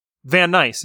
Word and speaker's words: Van Nice: It's It's, I know it's complicated because it's Dutch, but I think you did okay. – Wait, what's Dutch? – Van Nice. It's literally like it.